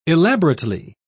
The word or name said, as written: elaborately